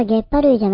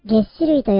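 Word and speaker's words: 齧歯類: 週末にウィザムスクエアの LINE グループでアキーさんが優しくあれはゲッパ類じゃなくてゲッシ類と読むんだよと教えてくれました。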